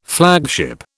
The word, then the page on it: flagship: British English